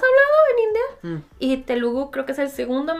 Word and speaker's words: Telugu: Este, de hecho, el idioma original de esta película que no está disponible en Netflix es el, te lo digo bien, Telugu.